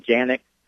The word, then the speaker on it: Canik: These are, Canik pistols. They are, Spell it. – C-A-N-I-K. And some people call them Janik. I guess Turkish, the C is actually pronounced as a J.